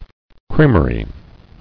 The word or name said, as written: Creamery